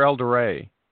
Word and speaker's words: Eldoret: Eldoret or Eldoret? – Eldoret, Kenya.